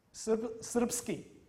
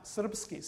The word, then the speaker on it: Srpski: Srpski, ja, Srpski, 5 und dann I.